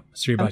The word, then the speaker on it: Suribachi: Or maybe it's Suribaki, which is a real volcano. – Chi. – Say it. – It's a chi. Yeah. Suribachi.